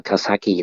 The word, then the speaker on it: Kawasaki: Kawasaki, hay cái người Úc nhiều khi họ đọc là Kawasaki đó.